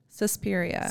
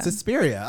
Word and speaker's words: Suspiria: Suspiria. – Suspiria, okay, it's right.